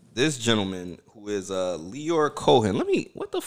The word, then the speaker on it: Lyor: Leo Lyor, however you say his name, Cohen. – Lyor. – It's Lyor.